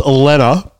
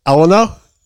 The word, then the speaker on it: Elena: It's Elena, but they say Eleanor. – Eleanor, okay. – Which is, it's E-L-E-N-A, which is, but a lot of people say Elena or whatever, but yeah, it's hilarious.